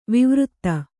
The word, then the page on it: ವೈವರ್ತ: vaivarta